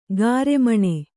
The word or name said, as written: ಗಾರೆಮಣೆ